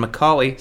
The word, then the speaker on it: Macaulay: You, I'm searchable via my name, which is Glenn with two N's, and Macaulay spelled like the same way Macaulay Culkin spells his name.